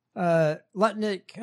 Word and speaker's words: Lutnick: Lutnick, I said it right.